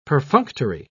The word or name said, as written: perfunctory